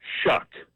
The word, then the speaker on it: Shough: They have a quarterback, Tyler Shuck. It's spelled S-H-O-U-G-H. Okay, I know that doesn't make sense, but it's Scottish Gaelic is what it is.